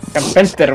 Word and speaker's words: Carpenter: Y además, eh, las últimas dos ya serían eh, la trilogía del apocalipsis de John Carter. John Carper. Carpenter, uy Oye, que estoy mal, malo para pronunciar.